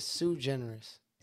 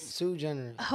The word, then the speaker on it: Suigeneris: So my actual name is Suigeneris. – Suigeneris? – Suigeneris.